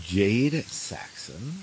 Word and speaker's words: Jad Saxton: I'm sitting with Jad Saxton.